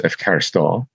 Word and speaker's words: ευχαριστώ: So even words in modern Greek that are written with an omega, mm-hmm. which in ancient Greek is a long O sound, so a word like thank you, which is ευχαριστώ, Americans tend to lengthen that last O, ευχαριστώ.